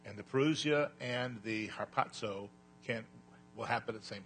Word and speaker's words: Harpazo: Harpazo. H-A-R-P-A-Z-O. Harpazo. And that's a long O, by the way.